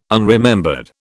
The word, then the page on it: unremembered: British English